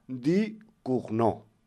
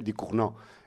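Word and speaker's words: Ducournau: Que por el nombre podría parecer de Rumanía, pero no, es francesa, ¿vale? Di Cugnot. Lo que pasa es que como se escribe Du Cournau, pues igual puede dar lugar a, digamos, a algún desliz, digamos, geográfico.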